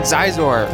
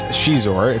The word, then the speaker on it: Xizor: It looks like Zizor. – Thing. It's Prince Shizor. It's spelt X-I-Z-O-R.